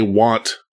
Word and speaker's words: wont: The second thing is when I did not say want correctly, as you would say someone is want to do. Because it is spelled W-O-N-T, and I'll be frank with you.